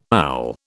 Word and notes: owl: British English

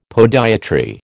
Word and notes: podiatry: American English

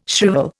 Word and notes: shrivel: Indian

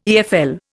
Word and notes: EFL: Indian

